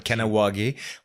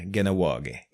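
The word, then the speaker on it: Kahnawake: C'est comme Kanawagé. On devrait dire Ganawagé.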